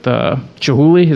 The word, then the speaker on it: Chihuly: But then, you know, I think his beef was with Chihuly. Is that how you say his name? – Yeah.